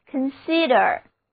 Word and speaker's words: consider: 一二字母组合同样发作 a, 重音放在第二个音节上，单词就读作 consider。